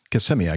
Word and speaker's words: Kissimmee: Give me a kiss. Kissimmee, I guess, is the way you pronounce Kissimmee.